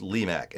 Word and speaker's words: Lemack: Joshua Lee Mack, his name is spelled Lemack, L-E-M-A-C-K. If you're a French leaning, then Lemack. But if you're listening to how he says it, he says Lee Mack, and he's quick to correct, but cannot pronounce Nevada.